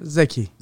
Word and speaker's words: Zeki: Zeki. – Zeki, and its last name is pronounced? – M-E-R-T.